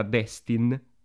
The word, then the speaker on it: Destin: il nuovo film della Marvel diretto da Dustin Daniel Cretton, ma penso si pronuncia in realtà Destin, tipo destino.